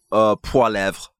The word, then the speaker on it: Poilievre: I would do it Poilievre.